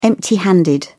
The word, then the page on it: empty handed: British English